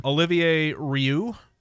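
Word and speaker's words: Olivier Rioux: Now, he's from Canada, so I am assuming the Canadian pronunciation here would be appropriate. – Okay. – Olivier Rieu. – Okay, that's how I would have pronounced it.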